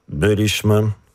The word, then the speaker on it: byliśmy: Наголос на третій склад від кінця. Увага! Притаманний дієсловам першої та другої особи множини минулого часу. Причому для обох родів. Що ми маємо на увазі? – Byliśmy, byłyśmy, byliście, byłyście, czytaliśmy, czytałyśmy, rozmawialiśmy i rozmawiałyście, dzwoniliśmy, przypuszczaliśmy, że nas słuchaliście, ba, liczyliśmy na to.